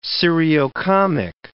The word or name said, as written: seriocomic